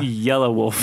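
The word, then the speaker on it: Yelawolf: It's Yella Wolf. – Yella, is it? – Yeah.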